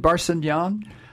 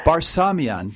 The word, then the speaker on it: Barsamian: We're now joined by David Barsamian. How do you say your last name, David? – Barsamian. – Barsamian.